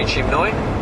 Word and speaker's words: Chinmoy: Turns out I am saying it right, Sri Chimnoy.